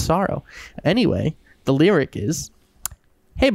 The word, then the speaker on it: $oro: Sorrow. It's like sorrow. Like, I feel lots of sorrow. But spelled with the dollar sign, R-O. Like, S-O-R-O.